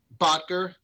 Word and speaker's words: Bottger: It's Berkman, Botcher, Newman, Schein. – Yeah, it's not. Botcher.